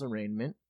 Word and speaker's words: arraignment: And then the kid spells arraignment. – No. Does he? I thought Bull spells arraignment, and he spells it wrong. And she's like, the G is silent.